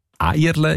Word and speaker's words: Eierle: Eierle ist laut dem Idiotikon eine typische Thurgauer Aussprache von Erle.